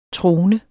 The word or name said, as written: trone